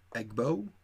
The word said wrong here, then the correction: Egbo: I believe it's pronounced Ebwo. – Ebwo, whatever. – That's what I've been told.